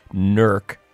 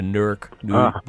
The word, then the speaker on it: Newark: Tonight, also an upside-down update, which is the news stuck inside of Weehawken with the Newark Blues again. I should pronounce it correctly, NERC, the NERC Blues again.